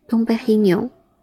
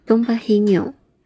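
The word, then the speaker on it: Dom Pérignon: 听到这里，唐培里农的法语怎么念呢？东北黑牛，东北黑牛，东北黑牛。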